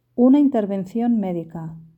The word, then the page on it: una intervencion medica: Spain Spanish